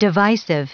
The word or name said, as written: divisive